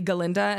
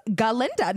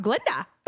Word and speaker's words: Galinda: It reminds me, like, if any of you have seen Wicked yet, it's like when Ariana Grande plays Glinda and her name is really Galinda and the professor can't pronounce it. And she's like, I don't understand why it's that hard. It's Galinda, not Glinda.